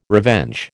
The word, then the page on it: revenge: American English